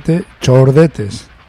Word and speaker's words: The Chordetes: En fin, yo os digo como, tal como se escribe, The Chordetes.